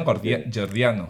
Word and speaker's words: gordiano: Gordiano.